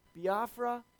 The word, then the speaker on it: Biafra: In this book, he describes how when Jobs was 13, he saw a magazine cover picturing starving children in Biafra, Africa? Am I saying that right? you know? Can I get a little help here, Melody? Is that B-I-A-F, Biafra, thank you.